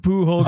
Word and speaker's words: Pujol: All right, so who was Juan Pujol Garcia? – Okay, Pujol. I know, like Albert Pujols. It's I'm quite certain it's pronounced Pujol. Anyway, he's from Spain.